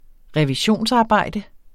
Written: revisionsarbejde

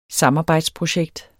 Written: samarbejdsprojekt

